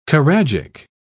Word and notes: choragic: British English

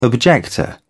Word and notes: objector: British English

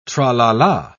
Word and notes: tra-la-la: British English

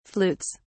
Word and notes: flutes: British English

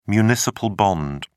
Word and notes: municipal bond: British English

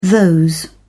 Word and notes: those: British English

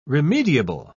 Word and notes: remediable: British English

